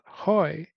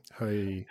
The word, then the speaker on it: Huy: Okay, now another name is hard to pronounce. Hoy, H-U-Y. – Yeah, Hoy.